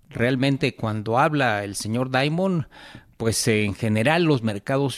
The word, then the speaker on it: Dimon: Dimon, el presidente de JP Morgan.